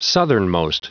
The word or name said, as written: southernmost